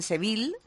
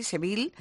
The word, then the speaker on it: Seville: Ellos pronuncian Seville, pero lo pronuncian así, Seville.